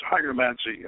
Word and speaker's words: hygromancy: And that's so it indicates that maybe this is a later version, this particular version of this this Solomon's hygromancy. What is it? How do you pronounce that? I forget. – Hygromancy would be correct.